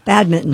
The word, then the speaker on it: badminton: No, we were talking about, I'm going to pronounce this incorrectly, badminton. You don't pronounce that N? – You do not. – No, badminton.